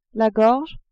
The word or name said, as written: gorge